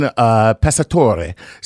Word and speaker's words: Pessatore: Let's move on to Dustin Pesatore. So, pronounce the name. – I can't see the spelling of it. – No, it's Pesatore. – P-A? – Nope. P-E-S-S-A-T-O-R-E.